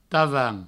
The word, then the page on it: tavan: provençal